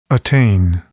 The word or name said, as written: attain